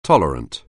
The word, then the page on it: tolerant: anglais